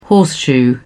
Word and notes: horseshoe: British English

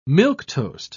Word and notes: milquetoast: British English

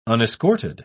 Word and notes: unescorted: British English

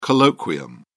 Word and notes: colloquium: British English

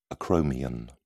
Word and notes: acromion: British English

